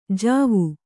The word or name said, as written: ಜವ